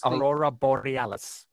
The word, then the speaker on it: Aurora Borealis: Ah, the Aurora Borealis. Did I say that right? – Yes, Aurora Borealis.